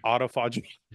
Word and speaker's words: autophagy: Autophagy. – Yeah. The proper pronunciation. – The proper pronunciation is autophagy.